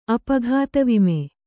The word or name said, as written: ಅಪಘಾತವಿಮೆ